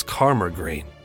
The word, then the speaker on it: Culmore Green: A bright little boy who'd heard the man speaking to Christine recalled that the man had pronounced Culmore Green as Carmer Green, which wasn't typical of an out-of-towner. That was the local dialect, the way to say it, and I probably fucked it up, but he said it the way that locals would say it.